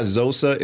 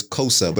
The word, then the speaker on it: Xhosa: Yo, and I misspoke. It's not Xhosa, it's Xhosa, but it's spelled X-H-O-S-A.